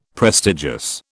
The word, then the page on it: prestigious: British English